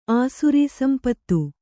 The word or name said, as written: ಆಸುರೀಸಂಪತ್ತು